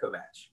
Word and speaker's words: Kovach: It's Croatian, and growing up just outside of Pittsburgh, Western Pennsylvania is very Eastern European, so everyone knows how to pronounce it up there. Once I left for school and moved down here, it gets butchered a little bit, but everyone's pretty close. Kovac is pretty close, but yeah, Kovach.